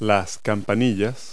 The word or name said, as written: bells